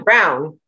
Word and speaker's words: Braun: It's like Christian Brown, which is spelled Braun.